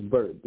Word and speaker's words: Bert: It's Bert. B-E-R-T.